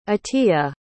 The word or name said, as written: atiya